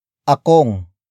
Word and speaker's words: akong: Walang wala, akong, pagkain.